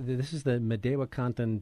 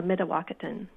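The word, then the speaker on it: Mdewakanton: So this is the Madewakanton Dakota is the tribe that inhabited that area, is that right? – Historically, the Madewakanton. – Okay, pronounced it wrong.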